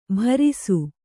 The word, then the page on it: ಭರಿಸು: Kannada